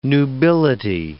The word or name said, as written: nubility